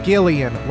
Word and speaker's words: Gillian: Gillian, like GIF. Honestly, her name, I think, is vindication for me and all the other heroes who pronounce it GIF with the hard G, not JIF, like the peanut butter.